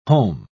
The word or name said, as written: holm